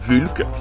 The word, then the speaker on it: Vulk: Vulk, ça s'écrit V-U-L-K, qui vient de sortir son nouvel album Vulk Esda.